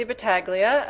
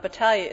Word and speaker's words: Battaglia: My name is Tracy Battaglia, and Battaglia is the correct Italian pronunciation.